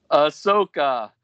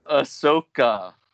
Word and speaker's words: Ahsoka: I'm curious what's going on with the, Asako? Asako? How do you say it? Because I can never say her name right. – Ahsoka. – Asako. Ahsoko. Did I say it right that time? – Ahsoka.